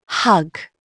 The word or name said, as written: hug